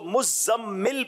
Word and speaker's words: مزمل: لوگ مزمل بولتے ہیں, اس کو مزمل پڑھیں گے کیونکہ تشدید ہے, تو اس کو پڑھنے میں تھوڑا سا احتیاط کرنی ہوگی.